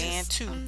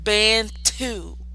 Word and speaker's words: Bantu: say it right. It's Bantu. Just, it's not that hard to pronounce. – It's Bantu.